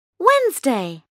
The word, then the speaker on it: Wednesday: Monday, Tuesday, Wednesday, Thursday, Friday, Saturday, Sunday. – As-tu remarqué que tous ces mots sont accentués sur la première syllabe et qu'on ne prononce pas le D dans Wednesday?